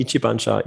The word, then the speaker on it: Ichibancha: Habe ich es richtig ausgesprochen oder war es ganz falsch? Benifuki, Pulver, äh, Ichibancha.